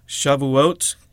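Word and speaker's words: Shavuot (שבועות): Shavuot is how you say this festival in Hebrew.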